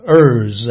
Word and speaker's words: err: Now, when you see the word E-R-R, that's a verb. And that verb is to be pronounced err. When you see the noun err, E-R-R-O-R, you pronounce that noun as err. So err, err.